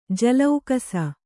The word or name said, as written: ಜಲೌಕಸ